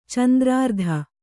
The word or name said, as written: ಚಂದ್ರಾರ್ಧ